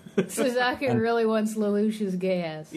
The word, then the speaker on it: Geass: I know it's pronounced gay ass, but it's still really good.